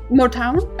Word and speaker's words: Motown: Is that how you pronounce it? Motown? – Motown? – Motown.